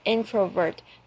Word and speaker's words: introvert: 如果你认为静止不动 2个小时是非常有生产力的，是强调 very productive, 因为你认为的 very productive 造成大家会觉得你应该是一个 introvert, 应该是一个内向的人 introvert introvert 要记得那个 introvert 它的声调 introvert 有点像是有趣的 interesting introvert 有没有发现它在中间都是上扬的 interesting introvert 对 If you consider 2 hours sitting still very productive Then you might just be an introvert.